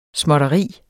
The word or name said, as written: småtteri